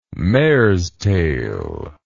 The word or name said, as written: mare's tail